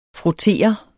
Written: frottere

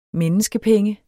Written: menneskepenge

